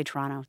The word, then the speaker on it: Toronto: They say Toronto. There's no T's in the middle of words in North America.